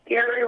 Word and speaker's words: Gary: Gary Roberts. – Oh, yeah. I'm sorry, Carrie is your first name? – Yeah. Carrie. – Spell it for me. – G-A-R-Y.